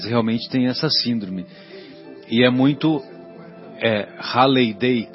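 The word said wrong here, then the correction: Riley-Day: Como é que se escreve? R-I-L-E-Y. Riley. Riley Day.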